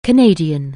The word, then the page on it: Canadian: anglais